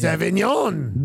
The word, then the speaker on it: Avignon: Which is in France under the surety of the Avignon Papacy. Oh, my God. – Avignon. – Avignon. – Avignon. – I had a Avignon. – The G is silent. It's Avignon.